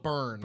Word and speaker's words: Byrne: it's B Y R N E. So I'm thinking it's so Burn. – Burn, Yeah.